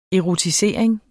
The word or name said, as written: erotisering